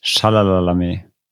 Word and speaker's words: Chalamet: Chalamet.